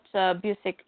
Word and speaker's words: Busiek: Yeah, if you and Kurt Busiek, if that's what I said.